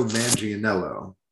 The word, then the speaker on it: Manganiello: Joe Mangianello.